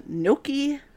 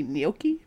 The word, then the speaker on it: gnocchi: gnocchi, I believe that's how it's pronounced, or possibly gnocchi, Thursday achievement in Nero. – I would have gone with gnocchi. – Gnocchi. – Kind of like bocce ball.